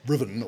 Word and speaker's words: Ruthven: And Lord Ruthven, R-U-T-H-V-E-N, but pronounced Riven or something like that, was widely assumed to be Byron himself.